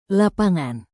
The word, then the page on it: lapangan: indonesian